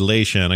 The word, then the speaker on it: Lei Shen: He's Emperor Li Shen, pronounced Lei Shen. I guess I'm saying it wrong.